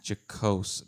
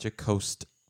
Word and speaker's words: jocose: Jocose, not jocost with a T at the end. – Correct. – Jocose. – Ends with an E.